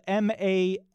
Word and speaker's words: mascarpone: It's spelled MASCARPONE, I have always said this just the way it looks, so I say mascarpone, but I guess I don't know beyond that. – You're right.